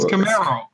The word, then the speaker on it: Camaro: It's not tomorrow. It's Camaro.